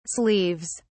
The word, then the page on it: sleeves: British English